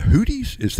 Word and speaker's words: Houthi: And do you pronounce them the Houthis? Houthi. – Yeah. Houthi.